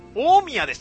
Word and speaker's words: 大宮: 結構有名なところでございまして、地下エア本店です。地下エアで合ってるのかな、読み方。串カツの本家でございます。読み方が間違えてる可能性もございますが、大宮でした。ごめんなさい。大宮です。大宮本店です。 – え？ – 大宮です。全然読み方ちゃいました。